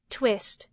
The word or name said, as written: twist